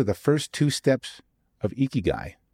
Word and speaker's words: Ikigai: Yes, it's pronounced Ikigai if you've heard of it before.